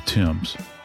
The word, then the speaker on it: Thames: One of the most historic waterways in history is the Thames. And it's the way it's spelled is not the way it's pronounced. It's actually T-H-A-M-E-S. But it's the Thames. Sounds like a guy's name, Tim.